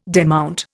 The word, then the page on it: demount: Indian